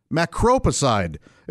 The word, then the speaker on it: Macropicide: Macropicide. It's macro, M-A-C-R-O, picide, as in he was beside himself. Oh, that's ridiculous. P-I-C-I-D-E. But it's pronounced macro. The emphasis is on that second syllable there.